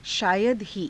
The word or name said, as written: shaayada hii